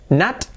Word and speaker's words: not: not, no digo not, digo not, como Natalia, Natilla, not, it's not a table, it's not a table, vale, tenemos todo bien pronunciado, it's not a table.